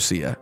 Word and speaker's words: Sia: I'm pretty sure it's pronounced Breathe Me. I mean, pronounced Sia. Breathe Me, this song. This is why I first knew Sia. Gabby doesn't know how to read phonetics.